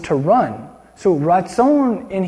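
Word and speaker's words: ratzon (רצון): Okay, so 1 verse 3, it says that word there for his acceptance, the Hebrew word for acceptance is ratzon. Everybody say ratzon. Yeah, ratzon. You could like spell that R-A-T-Z-O-N. Ratzon. Yes.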